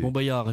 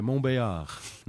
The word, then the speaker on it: Montbéliard: Montbéliard, effectivement, on ne prononce pas le L. – Ouais, Montbéliard.